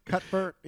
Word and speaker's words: Cuthbert: And Alicia Cuthbert, I'm like, I'm always not sure I'm saying her name correctly. – I know. – There's something in Cuthbert can't be correct. – Cuthbert, yeah. – But that's what it is, I think.